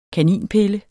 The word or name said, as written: kaninpille